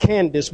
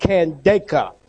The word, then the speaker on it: Candace: That word there is pronounced not Candace, but Candaka, queen of the Ethiopians,